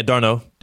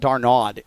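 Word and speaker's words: d'Arnaud: I said that correctly? – Yeah, Darno. – Darno. – Yeah. – Travis Darno, who I called Darnod yesterday, or D-R-Nod.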